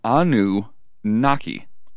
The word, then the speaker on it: Anunnaki: And if you're wondering how it's pronounced, it is Anunnaki.